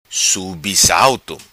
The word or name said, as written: Subissalto